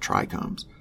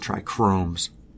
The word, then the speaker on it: trichomes: The hallmark of a bad buntender is hiding behind the technical side of the plant, including a memorable time a guy kept rambling about trichomes and then told me I was wrong when I tried to help him pronounce it correctly. He's saying trichromes.